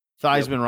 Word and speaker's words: Theismann: Theismann rhymes with Heisman. He changed the pronunciation of his last name.